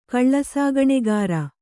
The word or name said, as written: ಕಳ್ಳಸಾಗಣೆಗಾರ